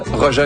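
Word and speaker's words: Rogê: Ça s'écrit R-O-G-E, accent circonflexe, Roger, un artiste brésilien.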